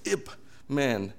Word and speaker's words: Ip Man: So it's Ip. I said, it's Ip Man. And he's a man, obviously, but that has nothing to do with that. The reason why Ip Man